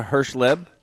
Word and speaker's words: Herschleb: All right, so at this time we're going to bring in our guest, Laura Herschleb.